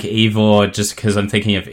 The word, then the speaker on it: Eivor: So you'll play as Eivor, I'm assuming it's pronounced. It's spelled E-I-V-O-R. Would you say Eivor or would it be like Ivor? I think Brendan went with Ivor.